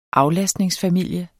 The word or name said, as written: aflastningsfamilie